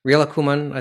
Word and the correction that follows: Rilakkuma: Rilakkuma, yeah.